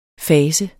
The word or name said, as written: fase